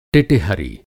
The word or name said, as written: टिटिहरी